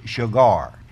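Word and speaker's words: shagar (שער): The gate, the word gate in Hebrew is sha'ar, and it's actually pronounced shagar.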